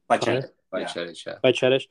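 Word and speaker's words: Bajčetić: And I think there was one play when an 18-year-old, Bajcetic, is that how you say his name? I don't want to say it wrong. – Bajcetic.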